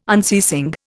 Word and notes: unceasing: Indian

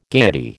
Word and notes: gaiety: American English

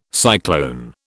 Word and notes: cyclone: British English

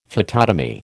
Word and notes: phytotomy: American English

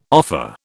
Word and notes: offer: British English

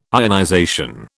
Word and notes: ionization: British English

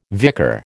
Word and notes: vicar: American English

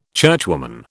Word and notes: churchwoman: British English

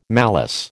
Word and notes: malice: American English

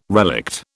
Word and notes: relict: British English

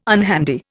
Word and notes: unhandy: Indian

